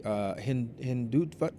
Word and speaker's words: Hindutva: This leads us into, and I don't know if I'm going to pronounce it correctly, Hindutva? – Yeah, then let's call Hindu fascism.